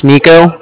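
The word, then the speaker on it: Niko: My name's Nico. That's N-I-K-O.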